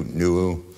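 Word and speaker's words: Nu'u: The Hawaiians have a memory of a man called Nua, N-U, apostrophe U, Nuu, which I'm told is probably a better pronunciation of the original Hebrew for Noah.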